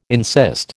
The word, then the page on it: insist: American English